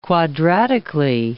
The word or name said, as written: quadratically